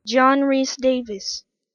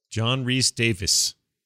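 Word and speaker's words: John Rhys-Davies: John Rhys Davies. – John Rhys Davies. So I was closer on the Davis part. – There isn't an I or an E in Rhys.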